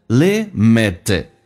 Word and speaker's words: limited: Always been only and extremely， and 跟 extremely 有一个零，首先 extremely 这个 TR 要说成 DR 要被浊化， extremely 在美式发音， and 连音叫 an extremely limited， limited T 发成 D。